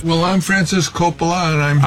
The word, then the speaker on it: Coppola: I found a clip of Francis Ford Coppola saying his own name, and he does, in fact, pronounce it Coppola.